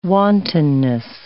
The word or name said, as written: wantonness